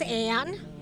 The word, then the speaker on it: Anne: My name is Anne. – Could you spell it? – A-N-N-E.